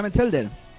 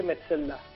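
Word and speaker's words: Metzelder: ¿A ti te gusta Metzelda? – No se pronuncia así, se dice Metzelda.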